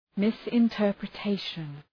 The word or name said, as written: misinterpretation